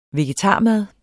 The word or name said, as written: vegetarmad